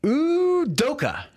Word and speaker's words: Udoka: My favorite new coach, at least I like to say his name, for the first time ever, Udoka. – There you go. – Does that work? – Yeah.